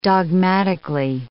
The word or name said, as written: dogmatically